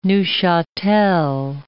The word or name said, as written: Neufchatel